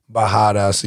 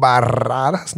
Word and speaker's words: Barradas: By the way, why do you guys have two R's, but then you fucking pronounce it with like it's a H? – H. – Bahadas. – Bahadas, but it's two R's. Shouldn't you be saying Barradas?